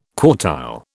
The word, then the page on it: quartile: British English